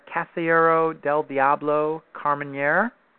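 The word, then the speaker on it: Casillero del Diablo Carmenère: Now, did you obtain the, am I saying it right here, the Casillero del Diablo Carmenere? – Sounds good to me.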